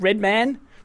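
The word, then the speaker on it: Redmayne: Like because Eddie, uh, Eddie, how do you how do you say Ray Red Red Man? Rain oh, Man. Oh, Redman, as Red in Red Man.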